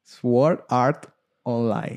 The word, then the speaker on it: Sword Art Online: Yo lo pronuncio como lo pronuncian en el anime. Sword Art Online.